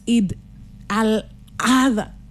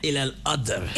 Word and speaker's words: Eid al-Adha: How you feeling this Eid al-Adha? That's how you say it. – Eid al-Adha.